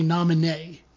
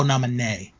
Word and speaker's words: Naminé: It's, uh, actually it's the it has more of an a sound at the end. It's actually Naminé. – Oh, Naminé.